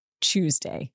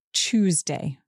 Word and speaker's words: Tuesday: So we have three different ways to pronounce Tuesday. I say Tuesday with a ch sound at the beginning.